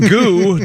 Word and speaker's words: Goux: We're spelling goo, G-O-U-X. – Is that how you pronounce it? – I would think goo or goa. It's not goux, I can tell you.